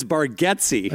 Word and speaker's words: Bargatze: you've always said my name wrong buddy so it's Bargetzi